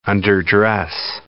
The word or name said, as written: underdress